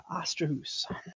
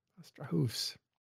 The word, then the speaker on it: Astrahus: How do we say that, Falcon? Is it Astrahus? – Indeed it is. Astrahus. – Astrahus.